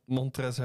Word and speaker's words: Montrezl: Montrezl Harrell. – Ja, maar dat staat toch echt een L? – Ja, maar die spreek je niet uit.